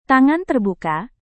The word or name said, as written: tangan terbuka